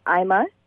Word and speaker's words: EIMA: It's pronounced IMA.